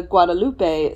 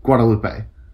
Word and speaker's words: Guadalupe: And so when people talk about the Guadalupe Street, I just call it Guad because they say Guadalupe. And I'm like, that is not how you fucking say it. Like, yeah. I know it's how you say it here in Austin, but for crying out loud, it's a common. Like, it's not one that is, like, in other places of Texas. It is Guadalupe. Like, yeah. Like the tallest mountain in Texas is not Guadalupe Peak. It's Guadalupe.